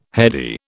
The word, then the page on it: heady: British English